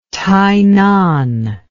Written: Tainan

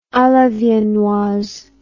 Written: a la viennoise

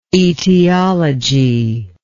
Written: etiologist

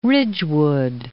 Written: Ridgewood

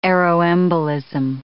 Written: aeroembolism

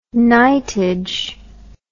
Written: knightage

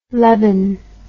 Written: leben

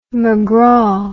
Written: macgraw

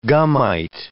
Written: gummite

